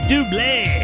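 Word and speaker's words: Double: Band is Dublé. – That's right. They pronounce it Dublé.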